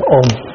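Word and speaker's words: Om: Không, án lam. Biết chữ án lam không? Tiếng của người ta đọc có một chữ thôi, ôm.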